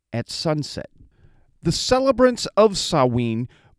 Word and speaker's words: Samhain: Now, some people call it Samhain because that's how it is spelled, S-A-M-H-I-N, or H-A-I-N, S-A-M-H-A-I-N. But it's pronounced Samhain.